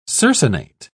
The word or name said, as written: circinate